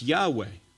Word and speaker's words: Yahweh: It's most likely to be pronounced Yahweh.